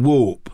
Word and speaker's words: Warp: W-A-R-P? – Warp. – Like the floor is warped.